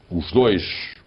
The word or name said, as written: os dois